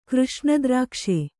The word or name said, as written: ಕೃಷ್ಣದ್ರಾಕ್ಷೆ